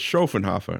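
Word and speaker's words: Schöfferhofer: I got corrected the other day when I was in the Germany Pavilion. – Oh. – Because I ordered. – You said it wrong in the Germany Pavilion? – Yeah. I said, I'd like a Schaffenhofer, please. And she said, Schaffenhofer? I'm like, yeah, that. She says Schaffenhofer.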